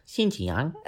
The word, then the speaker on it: Xinjiang: Que lo que ha pasado es que ahora han decidido que en una cierta localidad de China, que a ver si la pronuncio bien, se llama Xinjiang. – Xinjiang. – Sí, así digamos.